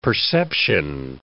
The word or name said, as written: perception